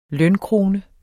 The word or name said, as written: lønkrone